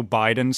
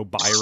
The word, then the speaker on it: Biden: And then they go up to another like homeless guy and he can't pronounce Joe Biden. So he keeps saying Joe Byron.